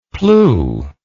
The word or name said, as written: plew